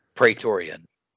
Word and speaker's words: Praetorian: Are they called Praetorian guards? Praetorian. Praetorian guards, which, um, shout out. Um, was that the Last Jedi? Yeah, the Last Jedi is where the Praetorian Guards fight Rey and um, uh, uh, Adam Driver.